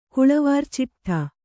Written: ಕುಳವಾರ್ಚಿಟ್ಠ

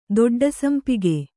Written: ದೊಡ್ಡಸಂಪಿಗೆ

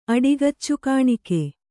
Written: ಅಡಿಗಚ್ಚುಕಾಣಿಕೆ

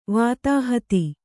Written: ವಾತಾಹತಿ

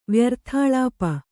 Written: ವ್ಯರ್ಥಾಳಾಪ

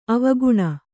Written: ಆವಿಕಣ್ಣು

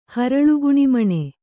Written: ಹರಳುಗುಣಿಮಣೆ

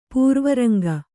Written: ಪೂರ್ವರಂಗ

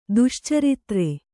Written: ದುಶ್ಚಾರಿತ್ರ

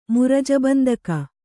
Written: ಮುರಜಬಂದಕ

